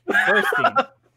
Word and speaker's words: Burstein: Zero Mostel, Chaim Topol, Alfred Molina, Danny Bernstein. Bernstein? I don't know how to pronounce it. – Gwen Stefani. – Danny Burstein. – Burstein.